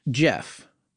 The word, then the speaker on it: Gef: But you know what it doesn't sum up, and what we've been hearing from a lot of listeners about is Jeff, which is spelled G-E-F, right? – Yes, well, it's a British thing.